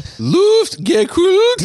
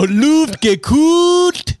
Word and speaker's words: Luftgekühlt: The K-U-H-L, the U is with umlaut. – Umlaut. – So it has to be pronounced like a gekühlt. – Gekühlt. Luftgekühlt. – Luftgekühlt. – No, Luftgekühlt.